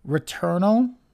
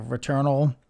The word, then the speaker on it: Returnal: The other game they revealed was a game from Housemarque Games called Returnal. I believe that's the way it was pronounced. Basically, it's spelled return with an A-L at the end of it.